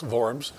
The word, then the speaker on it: Worms: And he wrote that song after he had gone and made his famous speech at the Council of Worms, it's spelled Worms, but it's Worms, and where he had said, here I take my stand.